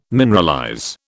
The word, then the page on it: mineralize: British English